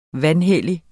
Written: vanhellige

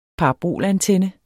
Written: parabolantenne

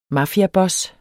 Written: MAFIABOSS

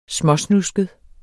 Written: småsnusket

